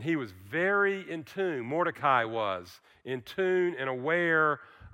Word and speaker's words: Mordecai: His name is Mordecai. Some I've heard it pronounced Mordecai.